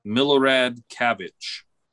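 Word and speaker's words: Milorad Čavić: Some believe that it should have been 7 gold medals, however, and that the 100 meter butterfly was actually won by Serbian Milorad Cavic.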